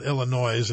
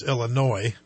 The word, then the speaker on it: Illinois: It's not Illinois. It's Illinois.